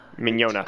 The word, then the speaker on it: Mignogna: We're speaking tonight with Vic Mignola? Mignogna. Mignola? uh, uh, Mike you, Mignola. – You... Mike Mignola. Vic Mignogna. – Mignogna. – Yes.